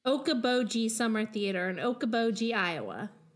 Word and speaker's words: Okoboji: Okoboji. Okoboji. – Okoboji, of course.